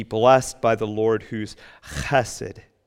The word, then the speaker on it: chesed: It's a Hebrew word that you would pronounce chesed. Chesed.